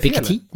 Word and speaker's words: Piketty: Är det Piketty? Nej, det är helt riktigt. – Det är rätt.